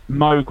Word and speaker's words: Moog: Moog was supposed to rhyme with Vogue. That was where it came from. He was like, that's how you're supposed to pronounce it. – But the Mooga Fooga doesn't work as the Mooga Foga. – I know, but that's how they pronounce it. That's how he pronounced his own name.